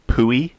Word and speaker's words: Puiu: Pui, that's just good. Well, yeah. It's spelled P-U-I-U, but, P-U-I-U.